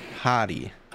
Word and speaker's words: Hati: Yeah, look up Shin Hati. But it's spelled Hattie. – H-A-T-I.